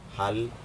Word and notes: hal: Aneityum

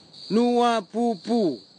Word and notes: nuapupu: kwamera; Tanna

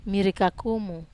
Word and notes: mari kakumu: Wamesa

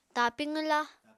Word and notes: tapi qwwla: Apatani

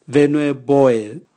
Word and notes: venue boe: Raga

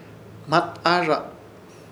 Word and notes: Hmataahrrrza: Ishir; Chamacoco

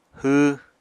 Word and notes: hww: apatani

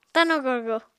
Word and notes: tano-gorgo: apatani

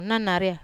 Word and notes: nanaria: wamesa

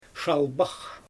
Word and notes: ʃalbax: Xyzyl

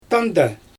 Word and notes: tanda: Xyzyl

